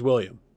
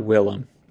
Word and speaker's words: Willem: Because you let's talk about Willem. Will EM probably, right? So it's Will EM.